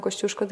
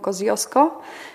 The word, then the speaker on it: Kościuszko: Australijczycy nigdy nie powiedzą Kościuszko, tylko mówią Koziosko. Nie potrafią wypowiedzieć Kościuszko, natomiast naprawdę jest bardzo, bardzo smaczne.